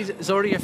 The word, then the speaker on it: Zorya: How many Zorya fans will be here tonight? And have I even pronounced it correctly? – You did well there.